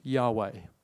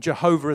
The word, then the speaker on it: Yahweh: So it was simply the letters YHWH, which when pronounced, and we're not sure exactly how it was pronounced, but most likely is pronounced Yahweh.